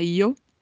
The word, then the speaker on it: yo: Pode ter som de I, por exemplo, na Espanha não se fala yo, se fala io. E pode ter uma pronúncia como se fosse um D e um J juntos.